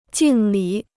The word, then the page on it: 敬礼: jìng lǐ